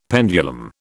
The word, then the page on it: pendulum: British English